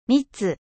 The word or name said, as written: nectar